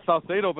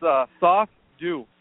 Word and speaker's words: Saucedo: Saucedo, but that was close enough. What I joke around and I tell people, basically it's Saucedo. – Sauce do, yeah, and I'm looking at it like yeah.